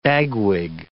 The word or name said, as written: bagwig